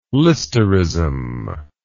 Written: listerism

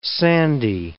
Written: sandy

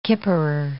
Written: kipperer

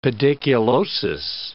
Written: pediculosis

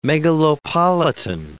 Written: megalopolitan

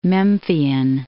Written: Memphian

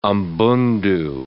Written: Umbundu

